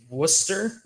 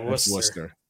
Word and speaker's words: Worcester: Yeah, Worcester. – Yeah, my bad. I'm sorry, from Worcester. Yeah, because that is the one city, town, whatever it is that is pronounced nothing like the way it's spelled.